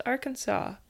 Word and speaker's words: Arkansas: Because it's spelled Arkansas, but it's apparently pronounced Arkansas.